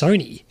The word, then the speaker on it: Sony: I didn't know that because I remember thinking, is it pronounced Sony? – Yeah. – As in the electronics manufacturer. – Yeah. Correct.